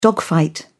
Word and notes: dogfight: British English

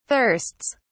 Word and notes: thirsts: British English

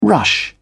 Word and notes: rush: British English

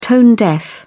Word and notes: tone-deaf: British English

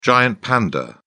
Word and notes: giant panda: British English

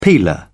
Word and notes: peeler: British English

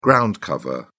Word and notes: ground cover: British English

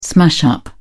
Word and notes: smash-up: British English

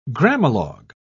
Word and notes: grammalogue: British English

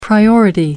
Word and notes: priority: American English

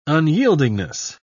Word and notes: unyieldingness: British English

